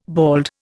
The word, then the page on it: bald: Indian